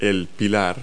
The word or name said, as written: pillar